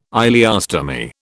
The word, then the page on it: ileostomy: British English